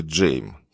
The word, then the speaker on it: Джейм: И требует, чтобы его произносили Джейм, как Джеймс, только без «С».